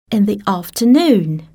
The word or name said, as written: in the afternoon